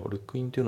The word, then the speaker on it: look into: で発音ですけど、 look into のところがリンキングで look into、 look into じゃなくて look into だね。